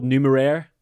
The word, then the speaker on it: Numeraire: It is technically called Numeraire.